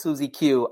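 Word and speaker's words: SuzieQ: So, the way you pronounce it is Suzy Q.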